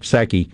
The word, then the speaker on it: Psaki: I look up, and, and Fox is on the left hand TV screen, and all I see is Jen, the P is silent, Psaki, uh, holding a press conference.